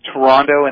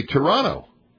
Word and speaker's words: Toronto: I was under the impression that you from Canada say Toronto. Toronto. – How did I pronounce it? – I heard a stronger second T than I am used to.